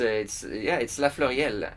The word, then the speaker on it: La Fleuriel: The pronunciation is quite good. It's La Fleuriel. It's a very French name.